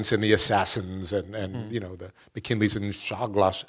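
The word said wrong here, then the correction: Czolgosz: How do you say Shulgash. – Shulgash.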